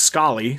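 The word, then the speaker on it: Scollay: So I looked it up, and it's pronounced Scully.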